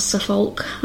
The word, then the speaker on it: Suffolk: It's spelled S-H-I-R-E, but I pronounce it more like S-H-U-H, like sh. So that's something that you can practice. It's a little bit difficult. And we also have Norfolk and Suffolk, so that's pronounced as this uk sound, but actually it's spelled O-L-K. So for example, Suffolk is S-U-F-F-O-L-K, but it's not Suffolk, it's Suffolk.